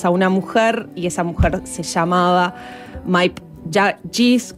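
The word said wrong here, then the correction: Miep: ¿Cómo le pronunciamos? – Mip. Me dijeron recién desde Países Bajos. – En la serie lo dicen Mip.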